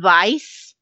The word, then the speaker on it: Weiss: it's pronounced Vice, but it's spelled W-E-I-S-S.